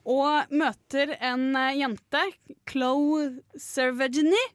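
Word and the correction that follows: Chloë Sevigny: Nei. Chloé Sevigny, er det ikke? – Ja. Eh, Chloé Sevigny, tror jeg.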